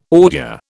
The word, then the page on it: ordure: British English